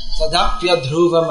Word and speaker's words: tadapi adhruvam: If you split up the words, then you'll say tat api adhruvam. But if you put it all together, then it's tat becomes tad, tadapi adhruvam becomes pronounced in a flow.